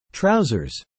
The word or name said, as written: trousers